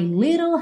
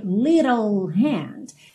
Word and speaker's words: little: Take me by my little hand. Esse little aí ganhou rarara, essa pronúncia rarara, nos dois T's. Se fosse uma pronúncia britânica, seria little, little, ok? Mas no inglês americano, little hand, and go like this, and go like this.